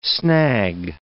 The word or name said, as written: snag